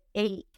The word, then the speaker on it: ache: Esa palabra dolor se escribe H-A-C-H-E-H, e pero resulta que a la hora de pronunciar se pronuncia ache. ache. Lo siento, muchachos, yo no me menté en las reglas, así se pronuncia.